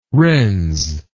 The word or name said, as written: wrens